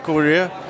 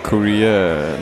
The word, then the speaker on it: Corea: Det er faktisk mere det, han hedder, har jeg hørt om, når han selv udtaler det, fordi det er sådan italiensk Corea. Chick Corea. Frem for det sådan her, vi kalder ham Corea.